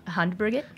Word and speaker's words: Handbrygget: How do you say it? – Handbrygget.